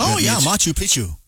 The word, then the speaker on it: Machu Picchu: It reaches all seven continents, and you get to see places like the Taj Mahal, the Great Wall of China, the Colosseum, Machu Picchu. I think that's how you say it. – Machu Picchu.